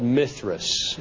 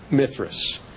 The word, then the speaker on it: Mithras: This god was called Mithras, M-I-T-H-R-A-S. If you want to spell it in Indian, it's M-I-T-R-A.